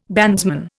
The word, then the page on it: bandsman: Indian